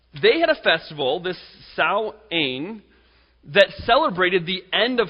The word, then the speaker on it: Samhain: It began around 3,000 years ago with a Celtic religious festival called Samhain or Samhain. It's pronounced those two ways. Now, it's spelt Samhain, but it's called Samhain or Samhain.